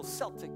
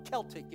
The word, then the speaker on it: Celtic: It looks a little Celtic. It looks a little Celtic if you want to make it a hard C instead of an S.